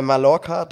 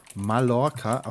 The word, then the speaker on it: Mallorca: ähm, und zwar heißt das Lied äh, Mallorca, da bin ich daheim. – Mallorca, auch mit L ausgesprochen.